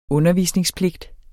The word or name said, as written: undervisningspligt